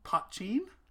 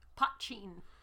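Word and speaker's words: poitín: And then they also have a really interesting one called Potcheen. – Potcheen. You have no idea how much of a struggle it has been trying to figure out how to pronounce this. It is spelled P-O-I-T-I-N.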